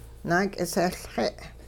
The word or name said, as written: naak'isehLxe'